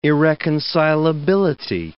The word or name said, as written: irreconcilability